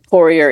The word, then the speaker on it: Poirier: Because my mother made sure that we had, it was even, A-N-N-E. – Yep. – And then Poirier is P-O-I-R-I-E-R.